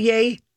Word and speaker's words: Ye: Am I pronouncing it right by calling it Yay? – Yeah.